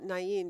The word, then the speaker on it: Nain: That's why when I see it, I call it Nain, because that's how it's pronounced in most Middle Eastern cities.